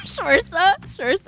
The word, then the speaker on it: Saoirse: That's how you say her name? – Yeah. Saoirse. – Saoirse? – Yeah. – Okay. Q-R-S-T.